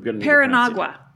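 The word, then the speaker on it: Paranaguá: I'm going to need you to try to pronounce it. – Different one. – Paranagua. Okay, that's good. See, it wasn't so hard.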